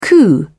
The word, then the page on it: coup: British English